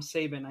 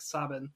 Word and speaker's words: Sabin: No, I, am probably I call him Saban. I know it's like Sabin. Uh, oh, is it? Well, I guess I was pronouncing it wrong too.